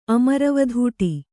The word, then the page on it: ಅಮರವಧೂಟಿ: Kannada